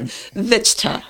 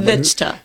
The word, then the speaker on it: Vichtcha: Vichtcha.